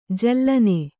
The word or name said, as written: ಜಾಲಿನಿ